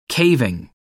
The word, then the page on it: caving: American English